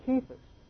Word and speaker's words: Cephas: So the correct pronunciation of this is really Cephas.